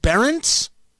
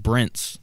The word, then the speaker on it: Brents: Is it Berentz? – I thought it was just Brents. That's it's the way Brents, it's spelled.